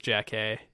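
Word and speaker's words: Jackée: I think it's pronounced Jack A.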